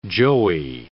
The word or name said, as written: joey